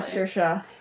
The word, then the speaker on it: Saoirse: Yeah, Saoirse.